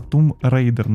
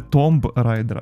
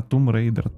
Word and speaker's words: Tomb Raider: Ні, ну взагалі то Тум, Тум. Тому що це англійською, це слово читається тум. Тому це Тум Рейдер, не райдер, не Томб Райдер, а Тум Рейдер.